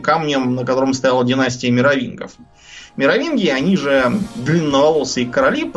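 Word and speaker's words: Меровингов: И вот с этого Хлодвига пошла династия э, мировингов. – А, они же мировингов. Мировингов, да. да. Я, я все время вот эти вот дурацкие римские, извините, варварские имена все время путаю ударение. Мировингов, разумеется. Каролингов, мировингов.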